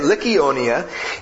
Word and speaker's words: Lycaonia: And that is how you say it, by the way. It doesn't look like it, but that is how you say it. Lycaonia, and to the surrounding country.